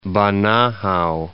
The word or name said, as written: Banahao, Mount